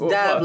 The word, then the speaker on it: Dav: It's pronounced Dav like now.